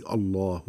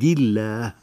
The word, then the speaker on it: الله: الله لفظ الجلالة ينطق بتفخيم اللام عندما تبدأ الجملة به الله أكبر، وعندما تسبقه ضمة هذا أمر الله، أو فتحة أطعنا أمر الله. وأما إذا سبقته كسرة هذا من عند الله فترقق اللام.